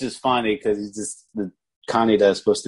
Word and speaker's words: Kaneda: Yeah, and I'm pretty sure they say the name in that version, Kaneda, is how they say it. – Like, oh yeah, yeah. Everybody calls Kaneda Kaneda just because it looks like it's spelled like Canada, but it's with a K, so mm-hmm.